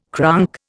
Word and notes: cronk: Indian

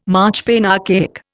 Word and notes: marchpane: Indian

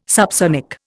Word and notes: subsonic: Indian

